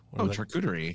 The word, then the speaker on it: charcuterie: Charcuterie. – Yeah. You can get one of those. – Charcuterie.